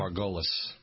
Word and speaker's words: Margulies: Is that how you pronounce her name? – Margulies.